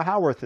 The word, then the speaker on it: Howarth: Uh, Howarth is the preferred Howarth, pronunciation.